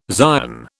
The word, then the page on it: Zion: British English